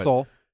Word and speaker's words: Seoul: It's Seoul.